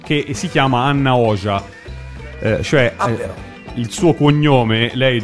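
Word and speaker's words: Hoxha: Il suo cognome vero si scrive H-O-X-H-A, e in albanese XH si legge J. E quindi Anna Oja.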